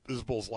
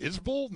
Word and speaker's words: Isbell: Its pronunciation's a little funky, and Joe was like, is it Isbell, not Isbell? I'm like, yeah.